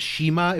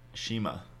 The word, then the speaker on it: CIMA: It's actually pronounced Shima if you look on Wikipedia. – Shima.